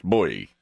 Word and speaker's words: Bui: And the second part of it is B-U-I, which is pronounced boy.